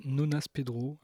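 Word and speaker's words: Gnonnas Pedro: Euh, j'ai choisi un morceau d'un monsieur qui s'appelle Nonas Pedro. Alors ça s'écrit avec un G, mais il me semble qu'on ne prononce pas le G.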